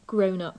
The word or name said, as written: grown-up